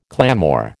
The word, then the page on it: clamour: American English